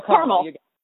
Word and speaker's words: Carmel: And am I saying it right? Carmel? Carmel? – No, Carmel. Carmel. You got it perfectly.